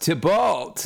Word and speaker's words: Tybalt: it's pronounced Tybalt. Like he kept emphasizing the second emphasis.